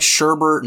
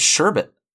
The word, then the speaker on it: sherbet: Well, it's like Sherbert and Sherbet. Like it's spelled Sherbet, but everybody says Sherbert, and it's like, no, it's Sherbet.